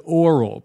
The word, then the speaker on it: aural: Now, that word, A-U-R-A-L, of or relating to the ear or the sense of hearing, is spelled aural, but it's pronounced oral.